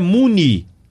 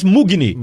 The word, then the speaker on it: Mugni: futebol que lá na Argentina é Muni. Muni, mas aqui no Brasil só chamam ele de Lucas Mugni. Mugni, Mugni, Mugni.